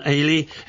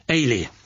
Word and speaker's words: Ailey: Say Donna, Grant, Campbell, aged 11, Ailey, aged 8. It's a Scottish form of Helen, and is pronounced like Hayley without the H. Ah, thank you for explaining to me. Ailey.